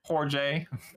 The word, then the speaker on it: Jorge: Jorge.